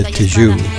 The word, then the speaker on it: Tijoux: Up now is 1977 by Anita Tijoux. Not sure if I'm pronouncing that right. T-I-J-O-U-X. Like Bijou?